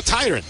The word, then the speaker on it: Tyrone: ¿Cómo se pronuncia? Bueno, por favor. – No es Miller, no. – No, pero es okay. Tyrone, ¿no? – Oh, Tyrone, sí, te felicito. – En San Félix decimos Tyrone.